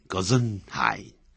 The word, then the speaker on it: Gesundheit: 当然有时候他用德文的啊，大致这样发音 Gesundheit，那么这是一个德文的发音啊，那这是个一般的英语讲英文的人还是用 God bless you 用比较多啊，我在这不再赘述。